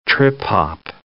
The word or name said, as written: trip-hop